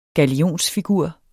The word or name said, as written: galionsfigur